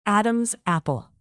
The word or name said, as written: adam's apple